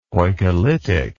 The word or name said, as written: poikilitic